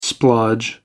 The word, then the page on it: splodge: American English